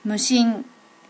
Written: mushin

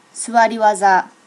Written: suwari waza

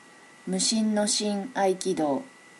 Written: mushin no shin aikido

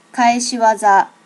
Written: kaeshiwaza

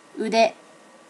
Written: ude

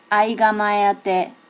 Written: aigamaeate

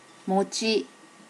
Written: mochi

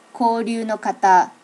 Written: koryu no kata

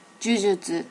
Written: jujutsu

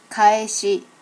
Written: kaeshi

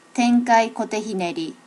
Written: tenkai kote hineri

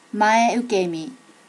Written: mae ukemi

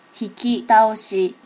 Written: hikitaoshi

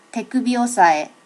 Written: tekubi osae

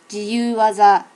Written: jiyuwaza

